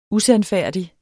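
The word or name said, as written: USANDFÆRDIG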